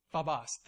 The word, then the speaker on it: phobos: It's the Greek word that's literally translated as phobos. Am I saying that right? Phobos? Phobos. Thank you.